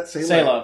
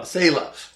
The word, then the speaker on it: Sela: Sela, uh, how do you say that? – Sela. Sela.